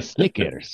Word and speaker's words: Snickers: El Snickers satisfies. – Yeah. That's awesome. I love the way that you said Snickers. That's the correct pronunciation in Spanish.